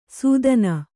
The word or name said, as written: ಸುತಾನ